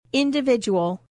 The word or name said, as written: individual